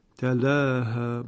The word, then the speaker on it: تلاها: اینجا استثناءً در قرائت همزه کوفی بدون اماله تلفظ می شود، یعنی می شود تلاها با فتح.